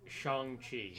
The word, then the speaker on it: Shang-Chi: And apparently, we've all been, mispronouncing his name for since when, the 70s? – Yeah. – Yeah, what's it supposed to be? – Shang-Chi.